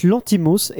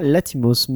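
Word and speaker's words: Lanthimos: Alors je crois que tu as très bien prononcé, mais on dit Yorgos Lantimos et pas Latimos.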